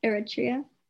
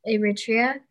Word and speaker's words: Eritrea: Yeah, Eritrea. – Eritrea.